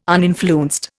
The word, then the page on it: uninfluenced: Indian